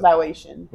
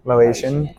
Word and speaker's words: Laotian: How do you pronounce it? – Laotian. – Laotian cuisine.